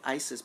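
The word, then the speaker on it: Iziz: Well, they say it like Isis, but it's I-Z-I-Z.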